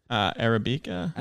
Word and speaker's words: Arabica: Well, this is a cold brew, but there's actually, it says organic Arabic. Arabica. What the shit does that say? – Arabica. – Oh my God, nice. That's how you pronounce that?